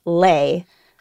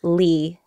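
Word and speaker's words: Le: So the traditional pronunciation of the last name L-E is Lay, but I'm used to calling myself Lee,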